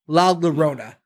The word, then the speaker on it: La Llorona: It's L-A-L-L-O-R-O-N-A. La Llorona? – La Llorona. – La Llorona. – We should do this whole thing over. We've been saying La Llorona, and it's La Llorona.